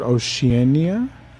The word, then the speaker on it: Oceania: Oceania.